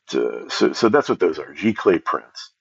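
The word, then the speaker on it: giclée: It's pronounced Geikly. But I think it's one of these words like Haagen-Dazs that somebody just made up to sound, you know, foreign and exotic.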